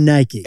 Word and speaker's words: Nike: One of the most historically mispronounced brands of all time. – What is it? – It's Nike. – It's Nike. – I mean Nike. – I like what you did there. Now, we've always said Nike, like two syllables. I feel like we grew up saying Nike.